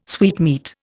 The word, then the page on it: sweetmeat: Indian